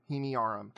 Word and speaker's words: Himiarum: H-I-M-I-A-R-U-M. Himyarum.